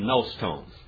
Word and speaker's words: gnostone: Actually, if we pronounce it right, we would swallow the gamma, which would be nostones.